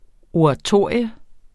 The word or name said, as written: oratorium